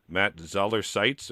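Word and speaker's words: Matt Zoller Seitz: Matt Zoller-Seitz. Am I saying his name right? – You are.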